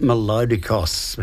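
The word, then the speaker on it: Melódicos: I presume that would be pronounced Melodicos. That would be a better way of putting it.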